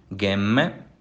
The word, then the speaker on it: Gemme: E as regiões que eu vou comentar são Gatinara, Gatinara e Gemi. Olha pronunciando essas palavras. – Gattinara, Gemme, Sesia e Sori.